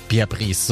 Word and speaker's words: Pierre Brice: Die nicht nur mit per Pierre Brice, Pierre Brice, so wird er ausgesprochen, mhm.